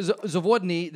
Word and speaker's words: Zavodny: I can't help but think that Dr. Zawodny, that you have a...